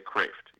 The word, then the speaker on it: Kreeft: I said Kreeft there. Hey, I got to say Kreeft.